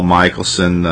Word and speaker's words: Michelsohn: My name is Paul Michelson. Last name is spelled M-I-C-H-E-L-S-O-H-N.